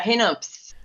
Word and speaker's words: Hennops: I did pronounce that correctly, didn't I? – Yeah, Hennops.